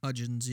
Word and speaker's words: Hudgens: Hudgens, yeah.